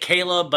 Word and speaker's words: Chleb: You say it, ah, you say it Caleb, but it's spelled C H L E B, but in my head, it's Chubbs.